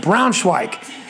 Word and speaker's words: Braunschweig: That's how you say it, Braunschweig.